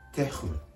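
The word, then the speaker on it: terme: 単語の後半のてふムは、これもハヒフヘホのふこういうような形で、てふム、てふムと発音します。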